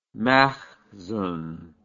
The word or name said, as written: makhzan